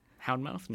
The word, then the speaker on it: Houndmouth: That was a track by Houndmouth. Say that right? There's no S in there, is there? – Houndmouth? No.